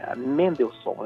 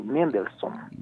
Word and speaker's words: Mendelssohn: Mendelssohn, né? Como é que se escreve? É Mendel, normal, né? M-E-N-D-E-L, depois S-S-O-H-N, S-S-O-H-N, Mendelssohn. – Mendelssohn, isso aí.